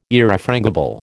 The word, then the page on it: Irrefrangible: American English